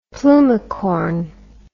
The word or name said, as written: plumicorn